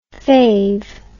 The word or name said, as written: fav